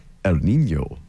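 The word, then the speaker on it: El Niño: Whenever we talk about El Nino, we have to say it the correct, proper way, which is El Nino.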